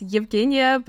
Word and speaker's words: Yevheniia: It's Yevheniia, but all my friends call me Zhenia.